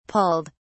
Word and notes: pulled: British English

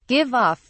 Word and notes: give off: British English

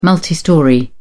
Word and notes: multistorey: British English

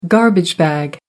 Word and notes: garbage bag: American English

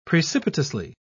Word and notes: precipitously: British English

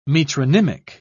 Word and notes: metronymic: British English

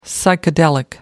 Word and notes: psychedelic: American English

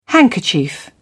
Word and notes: handkerchief: British English

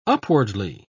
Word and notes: upwardly: British English

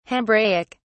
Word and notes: hebraic: British English

